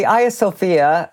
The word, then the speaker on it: Hagia Sophia: So the Hagia Sophia, and this is the Turkish pronunciation of the building, was built by the Emperor Justinian to replace a church that was destroyed in riots against him.